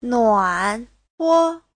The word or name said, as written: nuan3huo5